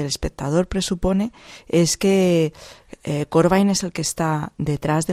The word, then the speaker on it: Corbijn: que Anton Corbijn, o Corbijn, que es realmente la pronunciación correcta en holandés, pues que Anton Corbijn fue el mismo a rodar en las seis localizaciones originales de cada una de las historias.